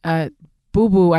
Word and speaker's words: Boubou: They just used this artist, Boubou, I believe is how you say his name, B-O-U-B-O-U.